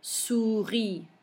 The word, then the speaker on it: souris: OU is pronounced OU. So let's do those two words again. Souris. Fourmi.